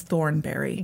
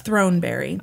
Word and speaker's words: Throneberry: And I keep wanting, every time I read her last name, I read it as Thornberry. – Yeah, no kidding. – But it's Throneberry.